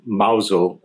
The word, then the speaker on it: Mousehole: a little fishing village called Mousel. It's spelled Mousehole.